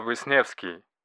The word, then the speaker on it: Wisniewski: And my name is Stanislav Wisniewski.